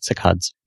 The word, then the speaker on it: saccades: saccades or something like that. – Yeah, that's it, saccades.